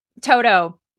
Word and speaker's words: Toto: Toto. Am I saying that right? – Toto.